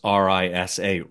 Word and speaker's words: Risa: Both Robbie and I would pronounce RISA, Riza, as if it was a Z, a Z.